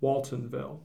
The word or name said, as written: Waltonville